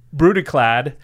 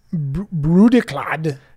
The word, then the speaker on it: Brudiclad: Oh, you give it a try first, and then I'll make fun of you for saying it wrong. – It's Brudiclad. – Totally wrong. – Telchor Engineer. Really, how would you say that? – Brudiclad. – It's Brudiclad. – Brudiclad. – Oh, if you say it like Scottish, then it sounds way better. It's like Mother of Runes.